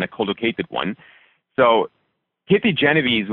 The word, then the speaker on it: Genovese: Do you know the pronunciation of her last name? – Genovese. Yep. – Kitty Genovese. Yeah.